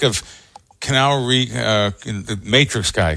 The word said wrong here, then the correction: Keanu: Am I saying his name right? Canal Reeves? – Keanu Reeves. – Keanu. That's the way you say Keanu.